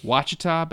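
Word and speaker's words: Ouachita: Hope I pronounced that correctly. – Wachita Baptist? – Yep.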